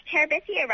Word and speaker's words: Terabithia: Yes, and you even pronounced Terabithia right.